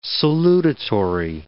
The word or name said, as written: salutatory